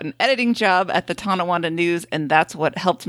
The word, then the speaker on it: Tonawanda: Andrew did pronounce Tonawanda correctly.